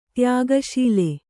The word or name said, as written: ತ್ಯಾಗಶೀಲ